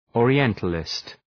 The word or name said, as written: Orientalist